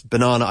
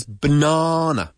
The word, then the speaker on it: banana: Nacho prefers banana. Banana. Ay, qué sílaba enfatizamos? Pues la segunda.